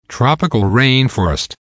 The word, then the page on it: tropical rainforest: American English